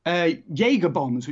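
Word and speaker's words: Jägerbomb: Jaeger bombs, which have I pronounced that correctly? – Yeah, you've absolutely pronounced it correctly.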